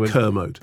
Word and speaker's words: Kermode: It's pronounced Kermode.